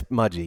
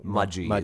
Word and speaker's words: Mudgee: Maji. It's pronounced Maji. – Ah, that's right. Yeah, I, I was looking at it. I'm like G L E N B R O O K. Uh, it's Mudgy. Yeah, Glen, Mudgee is where we in, are, in the Blue Mountains.